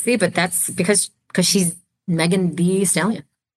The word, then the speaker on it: Megan Thee Stallion: Oh, there's an extra E. It's the. – I know it's spelled V, but I feel like I've heard it pronounced the. – That's how Megan goes by.